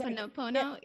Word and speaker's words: ho'oponopono: I just call it like ho'oponopono. I'm like, this practice. But it's ho'oponopono, yeah.